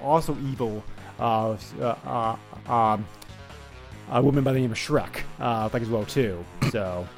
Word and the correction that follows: Shriek: Shriek actually, I think is how you pronounce her name.